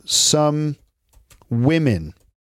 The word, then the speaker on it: women: So that plural, it sounds kind of like well, it's it it totally sounds like eh. So it's that O in woman, W O M E N. The O is pronounced with an I sound, and the E is also pronounced with an I sound. So we have women, okay?